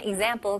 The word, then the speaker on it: example: 네, 오늘 단어들은 example 그리고 sample인데요. 차이점이 뭘까요? What's the difference? Let's find out. 네, 일단은 첫 번째 단어 example. 네, 여기서는 발음을 example. Example. G처럼 시작해서 그 다음에 Z로 넘어가는 거예요.